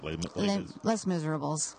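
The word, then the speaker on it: Les Misérables: Les Miserables.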